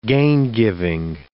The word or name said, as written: gaingiving